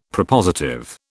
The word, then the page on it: Prepositive: British English